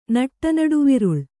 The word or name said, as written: ನಟ್ಟನಡುವಿರುಳ್